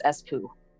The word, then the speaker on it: Espoo: This is spelled ESPOO. So I literally think it's S-poo.